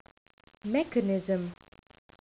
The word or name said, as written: mechanism